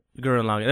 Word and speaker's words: Gurren Lagann: It's essentially the same creators of Garu Lagann. – Yeah, I don't know if I'm saying that right. – Gurren Lagann.